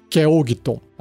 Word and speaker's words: Keoghtom: Se escreve K-E-O-G-H-T-O-M, Keogton. Acho que é assim que fala.